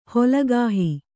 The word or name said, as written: ಹೊಲಗಾಹಿ